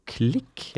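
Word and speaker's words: Klich: Um, så er det um, mange som gjerne vil at vi skal snakke litt om uh, vår polske midtbanespiller uh, eh, Mathius Klik, eller Klitsch, eller hva uh, han uttales? Klitsch, tror jeg han har sagt selv.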